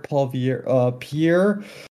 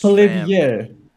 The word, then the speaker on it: Poilievre: Pierre Poilievre. He's French. – Poilievre.